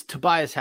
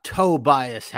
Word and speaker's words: Tobias: It's Tobias Harris. It's not Tobias Harris. Like when you say his name, it's like you're referencing his big toe, I.S.